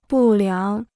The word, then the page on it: 不良: bù liáng